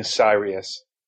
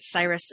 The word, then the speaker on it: Sirius: Yeah, I was saying Sirius. – Huh. – I think I said Cyrus in the beginning.